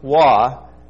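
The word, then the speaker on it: Wah (ו): The letter is pronounced Wah with a W, and that's all there is to it.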